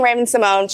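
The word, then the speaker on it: Raven-Symoné: But technically, if you want to pronounce it correctly, it's Raven Simone. But she said, if you continue saying Raven Simone, she said, don't trip.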